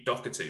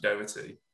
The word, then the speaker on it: Doherty: people used to say Pete Doherty, but it's actually Doherty.